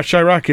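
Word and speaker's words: Chi-Raq: So the one you're going to start with tonight is Chirac. Am I pronouncing that correctly? – You are, Chirac. It's portmanteau between Chicago and Iraq,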